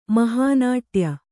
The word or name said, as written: ಮಹಾನಾಟ್ಯ